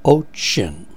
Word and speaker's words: WOUXON: The two major brands we have seen so far are the WOUXON, that's the brand name. It's pronounced Ochin. Gee, I'm glad they did that for me, ladies and gentlemen, because I've had to try that word out a couple of months in a row now, and I don't think I did a very good job. I don't think I made it sound like Ochin. It's spelled W-O-U-X-O-N, and the letters T-Y-T, Tango, Yankee Tango.